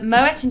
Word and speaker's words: Moët: Starting off with trivia, did you know that Moët & Chandon is pronounced Moët because the founder, Claude Moët, was of Dutch heritage? – I did.